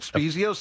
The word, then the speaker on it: Spiezio: First of all, next week I'll be having on the show, I don't know if I'm saying it right, Scott Spiezio? – Spiezio sounds about right.